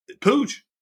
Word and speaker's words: Puig: Why are you so particularly fond of David Puig? – I'm about 98% sure that's not how you pronounce his name. Anyway. – How's it pronounced? – So you, Pooj.